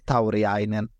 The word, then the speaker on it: Tauriainen: Eh, men ja, det ska ju nämnas att vi hade ju Billie G och Jimmy Taurainen då, Tauriainen, förlåt alla finska lyssnare, eh, Thomas Engstrand då, bland annat, eh, på hur det uttalas.